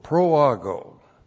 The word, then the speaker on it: proago: P-R-O-A-G, long O, proago.